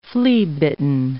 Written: flea-bitten

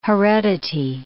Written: heredity

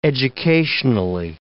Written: educationally

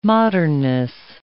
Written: modernness

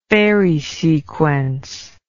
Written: Farey sequence

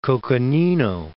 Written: Coconino Plateau

